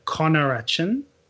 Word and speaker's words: conarachin: The other thing that I found really interesting when I was looking into the protein part, the proteins in the peanuts, which is it's pronounced arachin and conarachin, they are the components that people have the severe allergic reactions to.